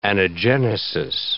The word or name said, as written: anagenesis